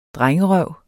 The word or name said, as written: drengerøv